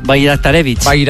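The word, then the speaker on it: Bajraktarević: El primero es un bosnio del PSV que se llama, voy a decirlo tal y como se escribe, Esmir Bajraktarevich. – Sí, señor, Bajraktarevich.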